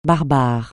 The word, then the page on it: barbare: francais